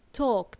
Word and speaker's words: talk: Okay, just to remind you guys, there is a silent L in this word, talk, T-A-L-K. So we spell it with an L, but we don't pronounce the L. So we say talk, like a long O, talk. And we don't pronounce L, okay? Remember that. Talk and walk as well. I don't want to hear L.